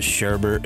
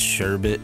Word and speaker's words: Sherbet: Do you know it's not, you don't pronounce it Sherbert, it's Sherbet.